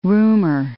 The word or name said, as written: roomer